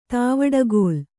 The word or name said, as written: ತಾವಡಕೋಲು